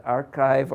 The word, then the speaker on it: arXiv: Because I have, is it archive or arXiv? Archive is the official. Okay. We could schedule a nice little discussion between Paul Ginsberg and myself about this. Many chemists have problems with archive.